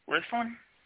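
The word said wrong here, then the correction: Wirthlin: It's just Worthlin.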